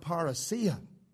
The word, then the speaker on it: parousia: It is the Greek word parousia.